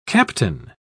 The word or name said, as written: captain